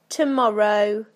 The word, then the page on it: tomorrow: British English